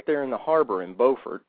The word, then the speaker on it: Beaufort: Now, there's a Beaufort in North Carolina and a Beaufort in South Carolina, and they're spelled the same, but they're pronounced differently. But this one is Beaufort.